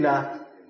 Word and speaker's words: Lillah: Lam, lam, ha. How you pronounce it? Lillah. Lillah.